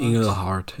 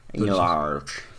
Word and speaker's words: Inglehart: Ronald Inglehart? – Inglehart, ja. Inglehart. – ähm, das ist eher amerikanisch ausgesprochen.